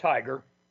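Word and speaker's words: TIGHAR: We call it TIGAR, like go get them.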